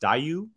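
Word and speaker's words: Daiyu: And I believe it's pronounced Dayu, if I'm not mistaken.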